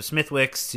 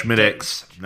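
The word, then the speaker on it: Smithwick's: Went to the Football Factory, had some tequila and some Smithwicks to drown the sorrows. But Schmidix, not Smithwicks. Schmidix.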